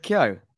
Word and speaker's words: Keogh: didn't actually see it, but I do know it was a header from Richard Coe. So, yeah, in terms of the game itself, From Richard who? – How do you pronounce it? – Richard Keogh.